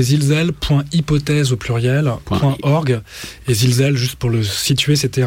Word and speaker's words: Zilsel: Donc, je voudrais rappeler simplement que tu es un des animateurs d'une revue qui s'appelle Zilzel. Alors, ça s'écrit Z-I-L-S-E-L. – Z-I-L-S-E-L.